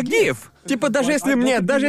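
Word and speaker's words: GIF: Если ты изначально объяснил, что это значит и как ага. это произносить, но ведь это, это уже ушло в народ и широко использовалось до того, как стало известно, что на самом деле Джефф.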